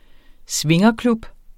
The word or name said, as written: swingerklub